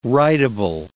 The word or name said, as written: rideable